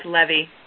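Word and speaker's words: levy: Olga, the word is pronounced Levi.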